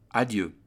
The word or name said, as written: adieu